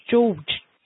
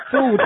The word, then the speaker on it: George: So tell me how you say your name. – George. – George. – It's George where I come from.